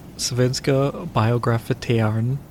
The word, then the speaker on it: Svenska Biografteatern: So, the first Swedish film company, Svenska Biografteatern, which is, I pronounced that flawlessly. And a lot of Swedish people are going to say I did it wrong, but I didn't. That's how you say it.